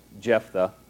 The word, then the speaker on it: Jephthah: You sound like you have a lisp when you say his name every time, but that's really how it's pronounced, Jephthah.